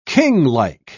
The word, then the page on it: kinglike: British English